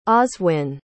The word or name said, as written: oswin